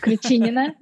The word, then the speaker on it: Kruchinina: Hope I've pronounced that somewhere close to being correct. – Very close to be correct. Kruchinina, yes.